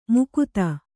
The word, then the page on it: ಮಾಗತೆ: kannada